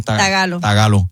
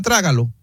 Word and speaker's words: tagalo: Espérate, hay un lenguaje que se llama trágalo. – Tagalo. – No, tagalo. – Oh. – No se dice filipino, se dice tagalo.